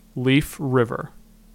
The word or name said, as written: Leaf River